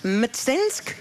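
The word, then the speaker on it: Mtsensk: Uit het district Mstensk, zeg ik het goed? – Ja, goed.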